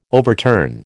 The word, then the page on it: overturn: American English